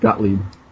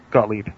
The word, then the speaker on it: Gottlieb: It's just like two syllables, like Gottlieb. – Gottlieb.